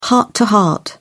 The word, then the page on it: heart-to-heart: British English